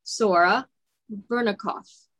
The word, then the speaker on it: Sora Verikoff: I'm here with my special guest, Sora Verikoff.